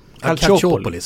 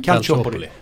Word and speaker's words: Calciopoli: Och jag kan inte uttala detta, men det är ju den här italienska Calciopoli. – Calciopoli, så säger man. – Calciopoli.